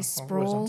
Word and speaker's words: Sproul: How do you pronounce that? R.C. – Sprawl, Sprawl, sprawl, Yeah.